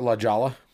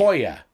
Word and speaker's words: La Jolla: And so I used to call it La Jala. L A J. – Okay, just stop spelling it. Just tell the story already. – L-A-J-A-L-A. Because I said it wrong. – Just La tell Jala. The flippin' story. – It's La Jolla.